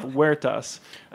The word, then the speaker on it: Huertas: I mean, the name of the restaurant, and I'll just say it myself, Huertas. I never realized it was such a hard thing to pronounce. – This damn H.